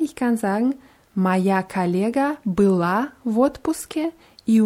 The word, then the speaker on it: была: Bei была ist die ganz deutlich auf a und у меня было много работы. Было ist auf ü, weil äh, sonst, wenn man было auch auf die letzten Silbe betont, dann hört es sich wie была an.